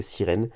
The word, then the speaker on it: Seiren: Donc, euh, ça, ça s'appelle, elle s'appelle 0100 Seiren, Sirène. Il me dit qu'on peut prononcer Sirène. Donc, 0100 comme les chiffres. Hum, donc, 0100, ça se dit Midem, Miden Ekato. Voilà, c'est ce qu'il m'a dit ça. Donc ça c'est gentil, ils m'ont donné les prononciations simples. Miden Ekato Seiren. Donc il m'a dit de prononcer sirène. Euh, mes connaissances sur cet artiste s'arrêtent à peu près là.